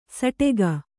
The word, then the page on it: ಸಟಕ: saṭaka